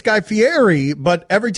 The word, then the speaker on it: Fieri: It's Fieri. It's Fieri. See, in Italian, E-R-N-I is rolled. It's Fieri. It doesn't have a T in it. Everybody wants to put a T in it, but it's Fieri.